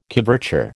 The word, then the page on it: coverture: American English